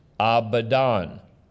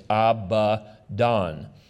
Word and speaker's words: Abaddon: Actually, it's pronounced Abaddon. Abaddon. If you look at the, but we anglicize it, and I've always heard it pronounced Abaddon. But if you look at the accent marks, you can see it's Abaddon.